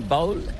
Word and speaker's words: bowl: Il se prononce comme bowl, mais c'est bowl. – Bowl, mais c'est pas comme un bol. – C'est B-O-W-L.